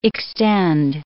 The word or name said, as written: extend